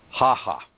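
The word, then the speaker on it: Hácha: Now, that's Emil, and his last name spelled H-A-C-H-A. The Czechs around here tell me that pronunciation is Haha.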